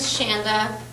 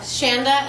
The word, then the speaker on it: Shanda: My name's Shanda.